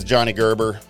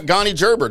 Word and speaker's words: Johnny Gerber: That is Johnny Gerber. Hey, I got your name right. – Finally. – You're not Gonny Gerber today.